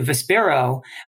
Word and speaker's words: Vispero: So to give you a little history to Vespero, which is spelled V-I-S-P-E-R-O, is actually the combination of two Latin words, one being visio and the other being spiro.